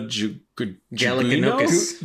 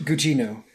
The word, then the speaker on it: Gugino: I don't ever know how to say this girl's name. Carla Galikinokas. – Gugino.